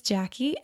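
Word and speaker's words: Jacque: My name is Jackie, and it's spelled kind of uniquely. It's J-A-C-Q-U-E, as in Jacqueline, without the L-I-N-E at the end.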